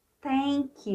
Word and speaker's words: thank: So, Lord, I thank you. E aí eu deixei o TH para a gente lembrar que a pronúncia é feita aqui na frente, ó.